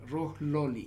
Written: Rohloli

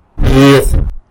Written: bied

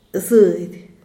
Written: zed

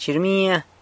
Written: chirmia

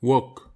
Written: wok

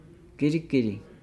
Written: giligili

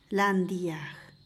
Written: lany dyag

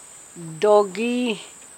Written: dogi

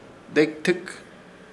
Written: Dekitɨk

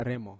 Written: remo

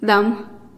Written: dam

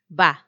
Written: ba

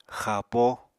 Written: xapo

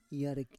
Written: yarek

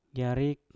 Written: yarik

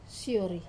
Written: sori

